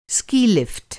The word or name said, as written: स्की-लिफ्ट